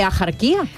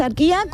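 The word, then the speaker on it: Axarquía: Escribimos asarquía con X, sí. ¿Vale? Pero ¿cómo lo pronunciamos? ¿Ajarquía? No, yo no conozco a nadie, a mí que me registren, pero yo no conozco a nadie que diga ajarquía.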